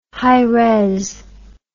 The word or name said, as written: high-res